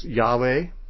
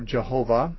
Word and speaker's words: YHWH: And when you add certain vowels to these, this word can be pronounced Yahweh or Jehovah or differently still.